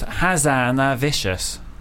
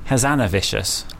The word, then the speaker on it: Hazanavicius: It actually is pronounced Hazanavicius. Hazanavicius.